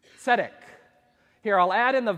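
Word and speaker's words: tzedek (צֶדֶק): Those are two E's, long E's, making it say tzedek.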